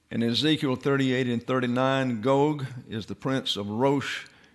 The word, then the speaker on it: Gog: It's Gog and Magog, actually. There's a long O there.